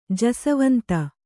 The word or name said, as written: ಜಸವಂತ